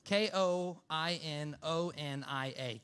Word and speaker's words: koinonia: So uh, the word I'm using is the word koinonia. Koinonia is spelled K O I N, like coin, but with a K. O N I A. K O I N O N I A.